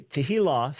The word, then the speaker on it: tehillah: Tehillah, so the accent on the last syllable, tehillah just means praise.